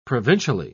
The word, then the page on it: provincially: British English